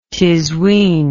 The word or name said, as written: tizwin